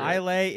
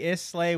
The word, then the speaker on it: Islay: Islay, Islay, Weelay.